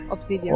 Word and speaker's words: Obsidian: Ja, Obsidian.